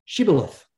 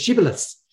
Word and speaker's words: shibboleth: And the reason they set that as the password is that they knew that the other tribe pronounced it differently. They pronounced it as shibboleths.